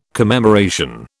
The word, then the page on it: commemoration: British English